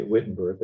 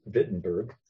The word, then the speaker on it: Wittenberg: What we use as a W gets a V sound in German. Like we say Wittenberg, it's Wittenberg.